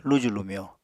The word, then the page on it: luju-lumyo: Apatani